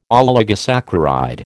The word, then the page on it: oligosaccharide: American English